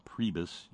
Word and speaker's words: Priebus: And his last name is pronounced with the long E sound, Priebus.